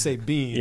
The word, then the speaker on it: Sabian: Sabian spelled S-A-B-I-A-N. – But pronounced Sabine, yeah.